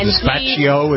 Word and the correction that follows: Despacito: Is that how you pronounce it? – Despacito. Not Gazpacho.